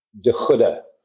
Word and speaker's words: De Goede: De Goede. So it's a hard G. It's Dutch.